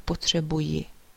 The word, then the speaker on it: potřebuji: So you can either say, as we said, já potřebuju, or you can say, or rather write, já potřebuji. So there is I at the end, short I.